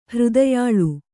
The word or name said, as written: ಹೃದಯಾಳು